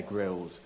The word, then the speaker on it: Grylls: How do you say it? I've only seen it spelled. Grill. It is Bear Grylls.